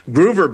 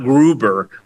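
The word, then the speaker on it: Gruver: Groover, by the way, is spelled G-R-U-V as in Victor, E-R, not Gruber with a B as in Bravo. G-R-U-V as in Victor, E-R.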